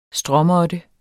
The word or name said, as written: stråmåtte